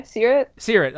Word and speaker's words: Seerat: Seerat.